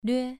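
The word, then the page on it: lve: tone 2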